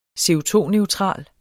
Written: CO₂-neutral

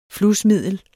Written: flusmiddel

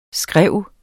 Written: skrive

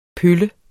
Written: pølle

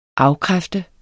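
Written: AFKRÆFTER

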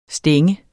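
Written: stænge

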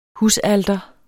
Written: husalter

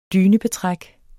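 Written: DYNEBETRÆK

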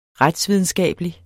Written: retsvidenskabelig

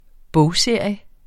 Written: bogserie

